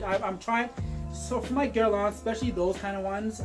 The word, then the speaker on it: Guerlain: Pronounce Guerlain. Guerlain. – It's in the throat. – Guerlain. – There you go.